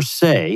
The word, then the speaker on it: Sey: First is Jennifer Say, and that's spelled S-E-Y.